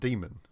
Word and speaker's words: daemon: Because it's still pronounced demon. – Daemon. – It's still pronounced demon. – Oh, okay. – It's just daemon.